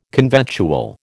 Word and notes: conventual: American English

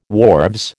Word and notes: wharves: American English

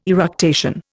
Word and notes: eructation: Indian

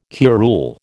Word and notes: curule: American English

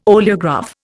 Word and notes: oleograph: Indian

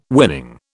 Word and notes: winning: British English

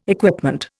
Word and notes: equipment: Indian

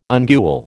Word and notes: ungual: American English

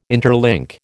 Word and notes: interlink: American English

